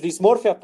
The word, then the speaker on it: dismorfia: Eh, la a ver, lo dije bien, la disformia este, es dismorfia, perdóname.